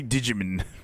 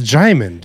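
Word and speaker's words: Djimon: Is that how you pronounce it? I always say Digimon. Digimon. – It's Jaimin, dude.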